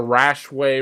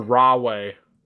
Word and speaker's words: Rahway: Yep, at the Rahway Recreation Center or Rahway. Sorry, yeah. New Jersey, I mispronounced it.